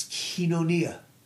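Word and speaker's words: koinonia: It's the Greek word kinonia. It's K-O-I-N-O-N-I-A. And the O is silent. So it's kinonia.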